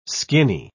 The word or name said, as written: skinny